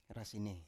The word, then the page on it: rasine: Biak